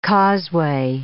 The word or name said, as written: causeway